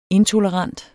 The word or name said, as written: intolerant